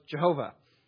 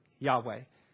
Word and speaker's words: Yahweh: And we're not totally sure that Yahweh is the right way to pronounce this, but we are sure that it's closer than how people used to pronounce this, which was Jehovah.